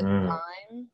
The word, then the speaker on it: thyme: First, I would definitely have to say thyme. I used to call it thyme because it's spelled T-H-Y-M-E.